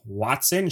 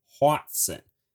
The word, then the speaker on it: hoatzin: Quick side note, I definitely did not just say the name Watson, Sherlock Holmes' partner. I am saying Hwaatzen. It does sound a lot like Watson, and I did double-check the pronunciation, especially because I suck at English, grammar, all that sort of stuff. But it is spelled, in case you want to use the Google machine after this, H-O-A-T-Z-I-N, and pronounced Hwaatzen.